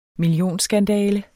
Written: millionskandale

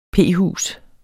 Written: p-hus

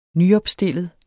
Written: nyopstillet

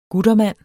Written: guttermand

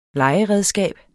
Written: legeredskab

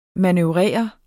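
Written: MANØVRERE

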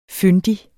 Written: fyndig

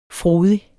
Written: frodig

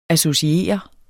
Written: associere

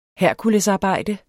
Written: herkulesarbejde

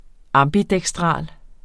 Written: ambidekstral

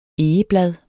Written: egeblad

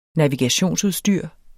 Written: navigationsudstyr

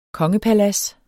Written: kongepalads

